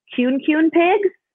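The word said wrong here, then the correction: kunekune: Cooney pigs.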